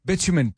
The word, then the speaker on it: bitumen: Bitumen, B-I-T-U-M-E-N.